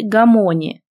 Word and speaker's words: Гамони: Он велел нам сесть на пуфы и затем сказал: «Мое имя Ламиаль Гамони. Запомните, ударение в фамилии на второй слог.